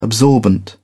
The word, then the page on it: absorbent: British English